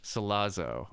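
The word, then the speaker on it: Sollozzo: Salazera. – What a sinister sounding name. – Is that what his name was? – It's Salazera or something. – Salazo. – Salazo. Okay. I added a few extra syllables.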